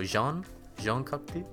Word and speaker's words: Jean Cocteau: The play is by Jean Cocteau. Is that how you pronounce it? He's French. So Jean Cocteau? – Yeah.